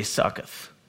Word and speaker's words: Sukkot: And Jacob journeyed instead to Sukkot, probably butchering that as I always do. I'm going to say Succoth, because I am not trained in this.